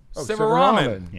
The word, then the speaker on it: Sivaraman: Is that how you say it? Sivaraman. – Sivaraman. – Yeah. – I'll take a bowl. – A lot of people turn me into a Silverman.